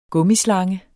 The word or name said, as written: gummislange